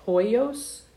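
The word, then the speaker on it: huios: And one of them was technon, if I'm pronouncing it correctly, and hoios. Is that how you believe it was pronounced? – Yeah.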